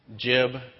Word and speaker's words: Jib: Jib is spelled J-I-B.